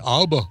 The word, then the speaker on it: ABBA: All right, before we go to food, can you pronounce ABBA or ABBA for us? How do you say it? – I would say ABBA. – That's correct. – Great. For those who are listening to the show after the fact, this didn't get recorded, but we talked pre-show about how to pronounce ABBA. And I've always said ABBA. So did that video. – The video said Aber. – Aber. – Or Aber.